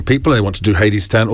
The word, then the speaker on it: Hadestown: And I need to apologise to you, by the way, because it's pronounced Hadestown. Am I right with that? Hadestown. – That's right, John. – Hadestown. But it's spelled H-E-D-E-S-T-O-W-N, so apologies for that.